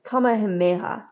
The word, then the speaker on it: Kamehameha: Francesco de Palamarin, a Spanish adventurer who became a trusted advisor to King Kamehameha. I'm so sorry if I'm butchering your name. – Kamehameha.